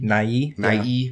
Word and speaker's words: Nighy: Almost all of the characters, you know, the Victor and played by Bill Nye, or Bill Nighy? – Nighy. – Yeah. – You had a syllable in there.